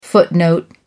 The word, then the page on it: footnote: American English